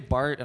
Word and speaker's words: Bart: I say Bart and I get Mark a lot.